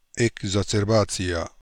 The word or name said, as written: Ekbarzerbazia